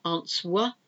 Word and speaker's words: answer: W-R-I-S-T. Wrist. And the answer to this is an answer, A-N-S-W-E-R. It's not answer, it's answer. So there's another silent W.